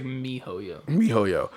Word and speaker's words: miHoYo: Muhoyo? Am I saying that correctly? – It looks like you are. It looks like Mihoyo.